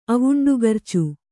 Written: ಅವುಂಡುಗರ್ಚು

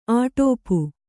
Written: ಅಟೋಪು

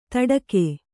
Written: ತಡಗು

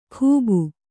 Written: ಖೂಬು

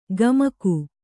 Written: ಗಮಕು

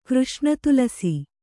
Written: ಕೃಷ್ಣತುಲಸಿ

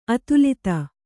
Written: ಅದಾಲತು

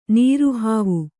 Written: ನೀರುಹಾವು

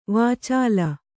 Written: ವಾಚಾಲ

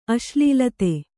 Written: ಅಶ್ಲೀಲತೆ

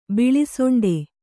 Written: ಬಿಳಿಸೊಂಡೆ

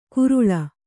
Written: ಕುರುಳ

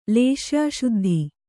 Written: ಲೇಶ್ಯಾಶುದ್ಧಿ

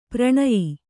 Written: ಪರಿಣಯ

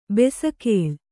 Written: ಬೆಸಕೋಲು

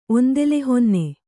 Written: ಒಂದೆಲೆಹೊನ್ನೆ

